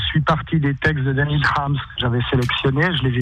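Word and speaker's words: Daniil Kharms: Daniil Harms. – Tout à fait, même on le prononce Daniil Harms. – Harms. – C'était la prononciation à la russe.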